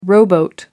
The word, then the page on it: rowboat: British English